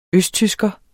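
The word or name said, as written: østtysker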